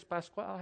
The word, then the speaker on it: Pascual: But it is an incorrect translation of the word Easter because it's the Greek word pasche, P-A-S-C-H-E. The Spanish word for Easter is Pascual. How do you say Pascual? Pascual. Pascual, yeah.